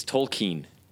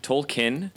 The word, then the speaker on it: Tolkien: Oh, we should tell everyone that it's it's Tolkien. That's really that's it. Tolkien is also like acceptable in the same way that the word lol is like acceptable. yeah. – Um, because people use it a lot, and, and, and the second pronunciation has been accepted.